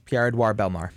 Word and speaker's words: Pierre-Édouard Bellemare: Taking a look at the box score, we had Bellemare. Is that how you say his name? – Yes, Pierre-Édouard Bellemare.